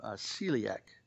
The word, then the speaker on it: celiac: And Kathy Maddie is asking for her niece Celia, who's home from hospitalization, but still having severe pain and complications from celiac. I did pronounce that correctly.